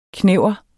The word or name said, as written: knever